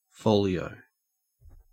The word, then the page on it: folio: Australian English